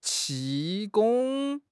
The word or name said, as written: 气功